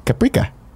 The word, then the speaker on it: Caprica: You said Caprica, which I like. It's like paprika.